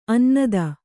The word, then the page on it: ಅನ್ನದ: Kannada